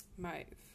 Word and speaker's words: Maeve: So, I don't know how you pronounce her name. Sorry, I'm just going to look up how I pronounce it. Maeve. Oh, I thought it was more complicated than that.